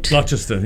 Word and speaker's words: Gloucester: just on Gloucester, is it? Did I say it right? – Gloucester, yeah.